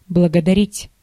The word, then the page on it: remercier: /ʁə.mɛʁ.sje/; France (Paris)